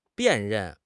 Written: 辨认

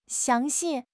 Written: xiang2 xi4

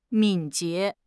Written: 敏捷

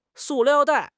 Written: su4 liao4 dai4

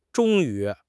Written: zhong1 yu2

